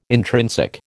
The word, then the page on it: intrinsic: American English